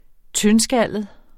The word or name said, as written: tyndskallet